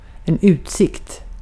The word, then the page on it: view: /vjuː/